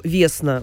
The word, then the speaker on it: Весна: Например, Антон назвал дочку Весна, ударение на Е.